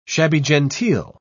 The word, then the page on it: shabby-genteel: British English